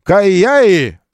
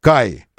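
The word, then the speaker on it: Kaiyi: Кайяи называется Кай просто. Кай. Легко запомнить. Как Kia, только перевертыш. Буквы меняются. Три буквы произносится этот бренд. Каи. Просто Каи.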